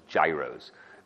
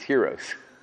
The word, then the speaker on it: gyros: Always thought it was Gyros. It's not. It's Heroes.